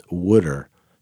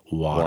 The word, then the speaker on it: water: His speech was, "It's pronounced wooder, not water."